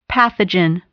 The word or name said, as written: pathogen